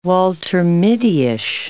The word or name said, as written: Walter Mittyish